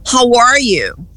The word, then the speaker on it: how are you: Porque eu ouvia do Eduardo e não a sua, mas a pronúncia correta é how are you? How are you? O A deles não é aquele A aberto, nosso, é mais fechado.